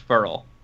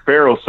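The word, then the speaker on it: Ferrell: As an ex-Clemson beat, thoughts on Cleveland claiming his last name is pronounced Furl? – I mean, I feel bad that it seems you see Farrell so often that, Right, because you've got to Farrell this whole time.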